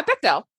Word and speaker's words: Bechdel: It's the Bechdel test, I think. Oh, yeah, Bechdel.